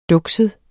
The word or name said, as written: dukset